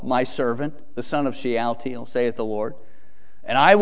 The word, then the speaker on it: Shealtiel: Son of Shealtiel. Shealtiel, or it's spelled a different way, Salathiel. By the way, Salathiel and Shealtiel is the same name, so when you see it, it's a little confusing sometimes.